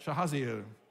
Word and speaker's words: Jahaziel: Everybody say that, Jahaziel. Did I say it right? Yes? Okay.